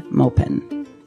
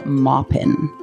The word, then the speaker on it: Maupin: In this episode, we will be discussing Tales of the City by Armistead Maupin, or Maupin, actually is how you pronounce it.